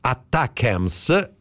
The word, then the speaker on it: ATACMS: Si tratta di missili ATACMS che, leggo dalle testate americane, si dovrebbe pronunciare ATACMS sostanzialmente, e sono dei missili che sui quali finora gli Stati Uniti avevano messo una limitazione.